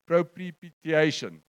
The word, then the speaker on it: propitiation: There's an English, other words that used in the English version, I think the ESV says it, propitiation. You come it now. You'll may not help. I've Googled it. There's a word that says propitiation. I'm pronouncing it right. Propitiation. That word.